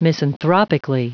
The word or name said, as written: misanthropically